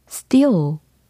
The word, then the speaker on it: still: steal, steal, steal.